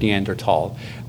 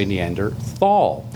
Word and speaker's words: Neanderthal: the correct pronunciation, if you're German, and it's a German word, Neanderthal, is like that, tall. – You're right. – With the TH in modern German is pronounced as a hard T.